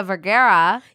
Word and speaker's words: Vergara: Okay, last one I'll talk about, which because I love her and him, is Sofia Vergara.